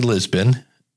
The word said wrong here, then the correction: Libsyn: Actually, I said it wrong again. That's the funny part about it. These are the two you hear, Lisbon and LibSync. It's Libsyn.